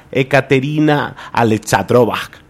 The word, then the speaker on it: Ekaterina Alexandrova: Tenemos que hablar que la tenista cucuteña comenzó con fuerza su participación tras vencer a la rusa Ekaterina Alexandrova, número 14 del mundo.